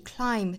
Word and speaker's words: climb: climb 这里面字母 b 是不发音的，所以直接是前鼻音的跟后面连读，就是 ma tree climb a tree.